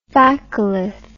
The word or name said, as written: phacolith